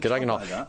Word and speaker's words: Cadogan Hall: We're doing a couple in late November and at Cadogan Hall. Is that how you say it? – Yeah. – I've heard different things, but Cadogan Hall. – Yeah, that works. – Okay, that works. – Cadogan Hall.